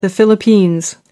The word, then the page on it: philippines: American English